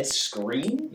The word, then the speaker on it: Skrein: Ed Skrein? – Yes. – Oh. – I think that's how you pronounce it. – S-K-R-E-I-N. Skrein.